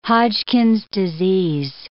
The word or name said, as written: Hodgkin's disease